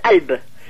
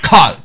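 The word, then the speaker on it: Kalb (قلب): Seuls les Druzes au Liban prononcent vraiment le kaf. – Kalb. Calme. – Mais le Coran, il s'est prononcé. Si vous écoutez un peu quelques psalmodies du Coran, le kaf est très bien prononcé.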